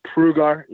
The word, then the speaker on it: Prugar: And for myself, my name is Jared Prugar. Obviously, it's really hard to pronounce my name. It's Prugar. You say it exactly how you say it.